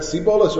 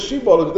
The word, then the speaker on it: Shibolas: They didn't say Shibolas. They said Sibolas. Rashi says over there in Source H, Megamgigim Veloshonam. They didn't pronounce it properly. The word is Shibolas. They mispronounced it.